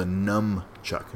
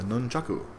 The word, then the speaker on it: nunchaku: I thought it was a nunchuck. No, it's a nunchaku.